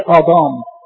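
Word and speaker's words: آدام (אדם): أولا آدم كلمة عبري، آدم كلمة عبري، نطقها في العبري آدام، نطقها في العبري آدام.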